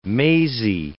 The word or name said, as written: mazy